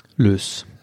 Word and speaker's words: LYZ: Oktober in Siegen im Lütz oder Lüz, Lüz, LYZ, wie auch immer man, wie würdest du das aussprechen? Lüz. Lüz. Im Lüz.